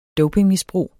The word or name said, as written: dopingmisbrug